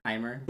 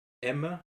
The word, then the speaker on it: Aimer: Aimer. – But apparently the pronunciation is Emma. Like Emmer? – Emma? Like, think of like, je t'aime.